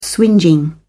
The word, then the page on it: swingeing: British English